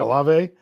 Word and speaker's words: Olave: Olave.